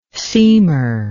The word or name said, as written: seemer